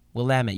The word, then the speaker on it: Willamette: It's actually Willamette Mountain. And I'm like, no, it's Willamette. Trust me, it's Willamette. And he's like, no, it's pronounced Willamette. You're in the Willamette Valley.